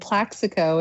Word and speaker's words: Plaxico: I'm most interested in the dog named Plaxico.